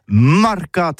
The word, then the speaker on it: mercat: Mais en gascogne toulousain, c'est prononcé sur les marcats, avec un A.